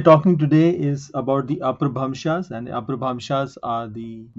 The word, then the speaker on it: Apabhramshas: Apabhramshas, some people also pronounce it like Apabhransh.